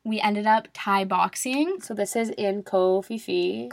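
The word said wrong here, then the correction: Ko Phi Phi: Ko Pipi. Ko Pipi. It's my It bad. looks like Ko Fifi, but it's actually pronounced.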